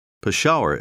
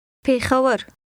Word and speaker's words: Peshawar: Peshawar, as we say it in English, is the southern pronunciation with the sh sound. Repeat the northern pronunciation after the speaker. – Peshawar.